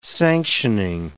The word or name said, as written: sanctioning